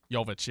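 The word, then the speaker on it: Jović: Jovic, yeah.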